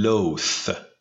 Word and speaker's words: loathe: فعل انزجار داشتن میشه to loathe. – To loathe. – To loathe. TH داره آخر این لغت. بنابراین اینو که زبونه دیگه هم باید بیاد بیرون و تلفظش یه چیزی بین ت و س باشه.